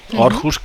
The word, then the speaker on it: Aarhus: Hi ha una altra ciutat gran, és Aarhus, que s'escriu Aarhus amb la O, una petita O a sobre de l'A, que quan és així s'ha de pronunciar com si fos una O, Aarhus,